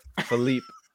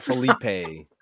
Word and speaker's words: Philippe: Philippe. It's Philippe Flop. – Yeah, but Philippe. It's not Felipe. – Yeah, like flip flop.